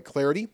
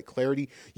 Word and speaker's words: Clardy: You can give me the follow at Troy Clarity. Last name is spelled C-L-A-R-D-Y, at Troy Clarity.